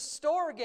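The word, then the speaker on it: Storge (στοργή): There's the Storge, it's how it's pronounced, love.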